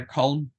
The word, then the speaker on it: Colm: Yeah, I get called lots of variations on it. I remember whenever I was a student living over in England, mm-hmm. the variations were wide. But yeah, Colm, yeah, it's the easiest way of pronouncing it.